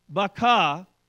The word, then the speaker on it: Baca: The valley of Baca, as they pronounce it in the Hebrew language, means a valley of weeping.